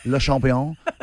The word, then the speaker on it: Le Champion: No, it's Le Champion.